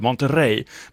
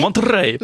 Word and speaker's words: Monterrey: Och han uttalade Monterrey på ett så härligt vis. Jag kan inte återskapa det själv. Monterrey, det är någonting med det rullande ärret.